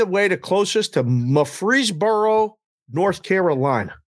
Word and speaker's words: Murfreesboro: It's Murfreesboro, okay. Tennessee, too. Same pronunciation, same town name.